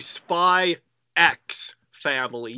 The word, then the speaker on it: Spy x Family: It's actually Spy X Family. You know, like Hunter Hunter has the X. It's another series with a silent X.